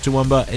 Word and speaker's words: Toowoomba: And I hate to say it, the only reason I know how to pronounce Toowoomba is because of Kathleen Jenner Overdrive.